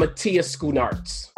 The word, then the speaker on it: Schoenaerts: Matthias Schoonartz.